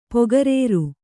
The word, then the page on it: ಪೊಗರೇರು: pogarēru